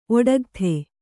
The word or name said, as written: ಒಡಗತೆ